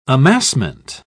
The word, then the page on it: amassment: British English